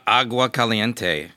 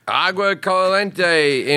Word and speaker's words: Agua Caliente: December 1st, I'll be at the, how do you say that? The Agua Caliente. – Agua Caliente in Rancho.